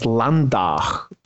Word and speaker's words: Landau: Also als äh, Beispiel ähm, Landau wird von Landauern selbst als Landach bezeichnet und Augen sagt man Ache.